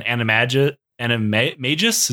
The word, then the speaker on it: animagus: She is an animagus. Is that how it's pronounced? – More or less, yeah.